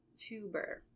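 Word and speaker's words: Teuber: It's by Klaus Tuber. That's how you pronounce the last name.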